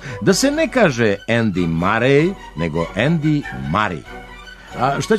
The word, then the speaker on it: Murray: Teniski turnir igra se u Šangaju, a Đokovićev protivnik zove se Andy Murray. Sportski komentatori su ga prvo prozvali Murray, ali to se ime nije toliko ustalilo u govoru da ne bi mogao da bude prihvaćen pravilan izgovor Murray.